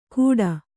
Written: ಘೂಟ

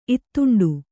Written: ಇತ್ತಂಡ

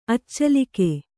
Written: ಅಚೇಲಕ

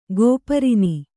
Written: ಗೋಪರಿನಿ

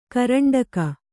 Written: ಕರಂಡಕ

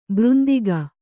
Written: ಭ್ರಾಂತಕ

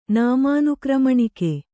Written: ನಾಮಾನುಕ್ರಮಣಿಕೆ

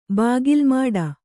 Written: ಬಾಗಿಲ್ಮಾಡ

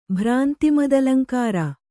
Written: ಭ್ರಾಂತಿಮದಲಂಕಾರ

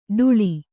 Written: ಡುಳಿ

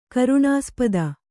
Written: ಕರುಣಾಸ್ಪದ

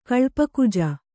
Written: ಕಲ್ಪಕುಜ

